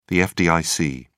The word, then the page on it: fdic: British English